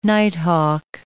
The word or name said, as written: nighthawk